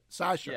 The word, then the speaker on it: Sacha: It's pronounced exactly like that. Sasha.